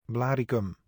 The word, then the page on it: Blaricum: [ˈblaːrikʏm]